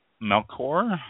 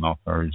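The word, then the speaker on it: Melchior: Melchor, I believe it's pronounced. I think it's a hard C on that.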